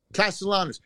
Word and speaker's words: Castellanos: Castellanos.